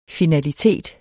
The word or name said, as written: finalitet